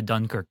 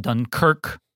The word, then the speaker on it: Dunkirk: And he knows it's pronounced Dunkirk, not Dunkirk.